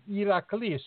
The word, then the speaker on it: Heracles: The H is not pronounced in Greek. So Hera is actually Hera, and Hercules is actually Heracles. You know, there's no H there.